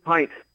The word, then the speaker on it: Pint: That would be and I'm not sure how to pronounce his last name, but I think it's Riley Pint, I believe. – Pint. – Pint. Okay, I was right. Chris and I talked about that. I thought it was Pint, but he said it was Pint, so I'm putting that one on him.